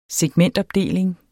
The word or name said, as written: segmentopdeling